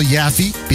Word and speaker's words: Yaffee: Yaffe being my last name, Y-A-F-F-E-E. You know, rhymes with Laffy, Taffy, all that.